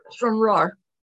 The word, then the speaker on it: Stranraer: Did I pronounce it correctly? Stranraer. – Stranraer. – Stranraer. – You have to get the R.